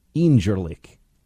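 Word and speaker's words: İncirlik: Incirlik, because in Turkey, the sea with the little thing underneath is like a J. Incirlik. And if you say it a little bit like Borat, you sound a little bit more Turkish.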